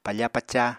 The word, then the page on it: pafa-paca: Apatani